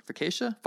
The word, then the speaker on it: focaccia: Focaccia? – Focaccia.